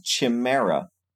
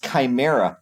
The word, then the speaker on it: chimera: It's not pronounced chimera. – Oh, you're a test read. – Chimera. – Anyway, it's chimera.